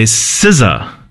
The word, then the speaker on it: SZA: But now we've got Saturn by SZA. – Yes. – I believe it is SZA, not SZA. – I did check. – That's the European pronunciation.